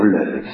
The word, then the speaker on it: Loew: Je suis parti du Père Leuve. Le Père Leuve, c'est un dominicain. Ça s'écrit L-O-E-W.